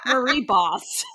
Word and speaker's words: Bosse: I'm saying boss, but it's spelled B-O-S-S-E. – Like okay. – Boss. Boss. I don't know. Marie Boss. Is it better? – There you go. Marie Boss.